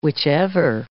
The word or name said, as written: whichever